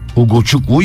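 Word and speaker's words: Ugochukwu: Uguçukwu yazıldığı gibi okunur abi yazmışlar.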